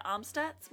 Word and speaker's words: Amstutz: You can find me personally on Twitter and other social networking platforms at Sandra Amstutz.